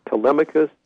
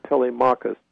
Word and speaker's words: Telemachus: I've heard his name pronounced Telemachus, but also Telemachus.